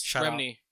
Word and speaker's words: Remini: Leah Remini? Is that how you say her name? – King of Queens? Remini.